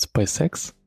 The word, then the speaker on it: SpaceX: Что у тебя произношение компании, в России все говорят SpaceX, а, видимо, в Штатах все говорят SpaceX. Я даже сначала не понял, про какую компанию ты говоришь, так на секунду залип.